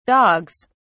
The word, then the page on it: dogs: British English